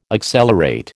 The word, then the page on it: accelerate: American English